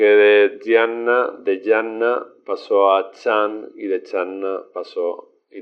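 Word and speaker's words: chan (禪): Originalmente chan, la palabra chan, se pronunciaba en chino dian, pero con el tiempo esta pronunciación cambió a chan.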